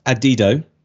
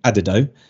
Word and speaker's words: Adido: It's pronounced Adido. I sounded like an idiot when we came on. I was trying to get it right, saying Adido, which sounds stupider the more I listen to myself.